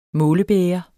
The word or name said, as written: målebæger